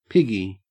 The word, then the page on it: piggy: Australian English